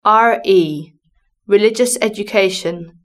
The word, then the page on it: RE religious education: British English